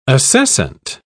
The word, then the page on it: acescent: British English